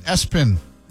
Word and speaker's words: ESPN: The name was ESPN, pronounced Espen.